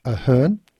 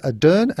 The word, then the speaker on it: Ardern: Speaking of music, we did have a couple of people tell us that I got Jacinda Ardern's name wrong. I called her Jacinda Ahern. I'm whacking myself on my wrist. It should be Jacinda Ardern, and I do apologise.